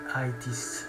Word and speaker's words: Aythis: cercate ITIS vi ripeto si scrive A-Y-T-H-I-S Ci avviciniamo verso la fine?